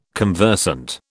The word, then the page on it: conversant: British English